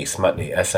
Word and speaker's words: Smutny: Is it Smutby? I know because the first syllable being the word smut sounds Smutney, Smutny. There we go.